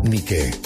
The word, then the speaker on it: Nike: Es interesante saber que Nike toma su nombre de la diosa griega de la victoria del mismo nombre, pero se pronuncia Nike.